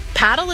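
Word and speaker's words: padel: Wait, is that Paddle? – No, that's paddle. Rhymes with saddle.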